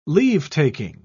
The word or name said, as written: leave-taking